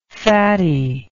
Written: fattiness

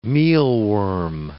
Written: mealworm

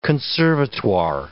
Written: conservatoire

